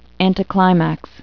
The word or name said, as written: anticlimax